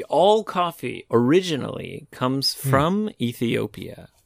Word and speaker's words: Ethiopia: Ethiopia is special.、エヒオピアっていう発音なんだね。日本語ではね、エチオピアだけど、エヒオピア。